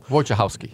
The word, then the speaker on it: Wojciechowski: Wojciechowski. – Wojciechowski.